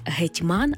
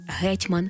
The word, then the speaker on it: гетьман: Втім, у цьому словнику не «гетьман», а «гетьман», а деякі слова подано з двояким наголосом, як от «завжди-завжди», «також-також», «договір-договір», «простий-простий».